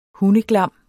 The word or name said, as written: hundeglam